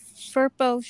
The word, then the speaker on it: Firpo: I believe you were pronouncing it perfectly, but I don't know what a Firpo is.